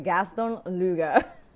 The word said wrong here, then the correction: Gaston Luga: Gaston Luga is proud of its Swedish design, vegan products, free delivery, and returns worldwide.